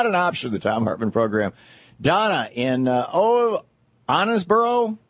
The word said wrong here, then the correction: Owensboro: Kentucky? Am I saying that right, Donna? – Owensboro.